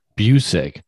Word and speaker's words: Busick: Busick. See, I was like, Buckus. Wow. I'm like, okay, that's going to be offensive because I Buckus? Definitely didn't say it right.